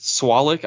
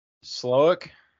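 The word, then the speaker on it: Slowik: Is it Swalick? I want to say Swalick. Like he's swole, but that's not – I don't know how you say it. – I think it's Slowick. – Slowick. Perfect. There it is.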